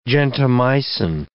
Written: gentamicin

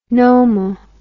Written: nomo

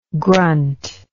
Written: grunth

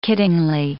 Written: kiddingly

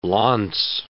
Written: launce

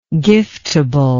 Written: giftable

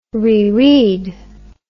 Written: reread

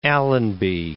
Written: Allenby

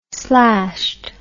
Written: slashed

